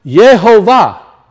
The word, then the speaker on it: Yehovah: It's pronounced Yehovah. Now, this is not just my statement. This has been proven out by many of scholars. That's how you pronounce it.